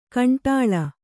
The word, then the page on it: ಕಂಡ್ಲಾ: Kannada